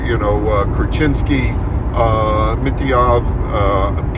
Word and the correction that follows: Mityukov: God, I probably butchered all three. – Mityukov. – Thank you, Mityukov.